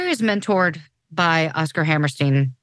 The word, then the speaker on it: Hammerstein: It's just, if I say Rodgers and Hammerstein, I say it correctly. If I look at it, I'm like, that's a Steen. – Doesn't make sense.